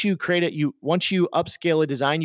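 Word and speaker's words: Upscayl: But I would still recommend, even if you're using some of the built-in upscaling tools, use a dedicated upscale software. There's one that I've been using, which is free, and it's called Upscale, but it's spelled funny. it's spelled U-P-S-C-A-Y-L. U-P-S-C-A-Y-L.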